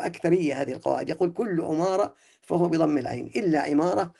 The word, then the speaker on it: عمارة: نعم، ونحو من هذا النوع أيضا عمارة، كله يقولون كل ما ورد من قولهم عمارة فهو بضم العين إلا عمارة الصحابي فبكسرها.